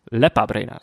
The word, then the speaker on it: Lepa Brena: Daarom draag ik Jugo, Jugoslovenka aan van Lepa Brena. Lepa Brena, sorry. Goed dat hij de uitspraak erachter zet.